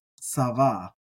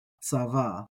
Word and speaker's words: צוה: And it's pronounced sava, sava.